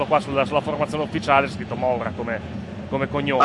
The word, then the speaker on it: Moura: Moura, quello è Moura la pronuncia corretta.